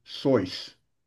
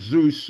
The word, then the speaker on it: Seuss: His name was really pronounced Soyce, but everybody kept pronouncing it Zeus, so he said, okay, let it be that way.